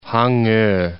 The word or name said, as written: Hango